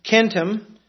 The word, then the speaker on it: centum: Now, the linguists have arbitrarily labeled these trunks with words for hundred using the Latin centum, pronounced with a hard consonant sound, and then the Avestan satim, which starts with a sibilant.